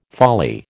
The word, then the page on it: folly: American English